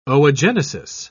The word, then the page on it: oogenesis: British English